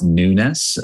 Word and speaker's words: Nunes: It's pronounced Nunes. Phonetically, it would be like N-E-W-N-E-S-S is correct.